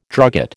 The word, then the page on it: drugget: American English